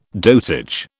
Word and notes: dotage: British English

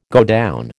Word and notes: godown: American English

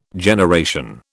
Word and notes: generation: British English

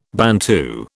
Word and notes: Bantu: British English

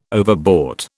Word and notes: overbought: British English

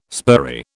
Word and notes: spurry: British English

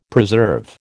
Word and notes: preserve: American English

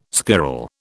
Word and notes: squirrel: British English

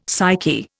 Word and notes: psyche: Indian

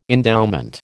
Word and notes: endowment: American English

